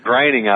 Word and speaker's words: Groening: Yeah, Matt Groening, I believe, with the Simpsons.